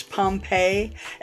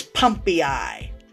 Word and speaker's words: Pompeii: the guy who plays the mayor pronounces Pompeii as Pumpei because it's spelled P-O-M-P-E-I-I.